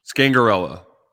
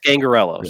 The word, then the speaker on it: Scangarello: Do you guys think Scangarello will – how do you actually pronounce it? Scangarello? – Scangarello. – Scangarello. Yeah. Scangarello.